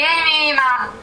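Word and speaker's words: Aimee Mann: aiutami a pronunciarlo, mi hai detto il titolo durante la pausa, il nome dell'autore, però non lo so pronunciare. – È di Amy Mann.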